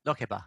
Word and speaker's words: Lokeba: Lokeba, which is spelled L-O-K-E-B-A.